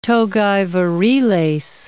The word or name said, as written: togae viriles